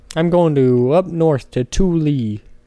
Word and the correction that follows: Tooele: Okay. Tooele. Who spelled Tooele? – How do you spell it? – T-O-O-E-L-E. Tooele. Thule. – Yeah, that Thule. – Yeah, it's Tooele. Who the hell dropped out of third grade and decided to name cities?